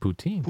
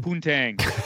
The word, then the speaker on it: poutine: Poutine. – Poontang.